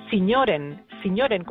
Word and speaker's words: Sinjoren: Sí, porque además, sí, claro, se pronuncia, si la lees tal y como, si la pronuncias tal y como la lees, es Sinjoren. Sí. Pero si la pronuncia, esto dicho en flamenco, se dice Sinjoren, como si fueran señores.